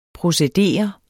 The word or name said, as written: procedere